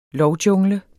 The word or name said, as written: lovjungle